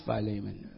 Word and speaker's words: Philemon: And in the Greek is Philemon, pronounced Philemon.